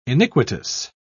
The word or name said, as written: iniquitous